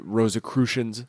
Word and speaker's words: Rosicrucians: I'm going to mispronounce it, Rosicrucians. No, I pronounced it right. – That's right, yeah.